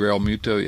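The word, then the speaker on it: Realmuto: How do you pronounce his name? Real Muto or JT Real Muto? Yes.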